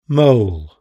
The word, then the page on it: mole: British English